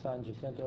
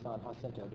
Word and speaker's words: San Jacinto: The event is hardly that much of a battle that brought about Texas independence is the one called San Jacinto or San Jacinto, depending on how you pronounce the J, put it both ways.